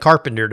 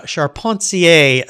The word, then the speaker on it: Charpentier: And listeners, I was kind of teasing John at the beginning here, because when I look at that last name of yours, it looks like Carpenter to me. But Charpentier is the correct pronunciation, right?